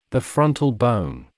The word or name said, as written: the frontal bone